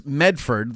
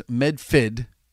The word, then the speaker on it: Medford: Well, there's three ways to pronounce Medford. There's Medford, the way it's written, so the way it's spelled. There's Medfid, which is it's Medfid. Medfid. – Okay. – And there's Meffa.